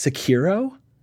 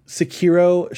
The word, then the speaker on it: Sekiro: Next was, I don't really know how to pronounce this, Sekiro? – That sounds right. – I think it's probably right. – They didn't pronounce it in the thing, so. – Sekiro, Shadows Die Twice.